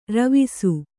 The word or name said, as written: ರವಸ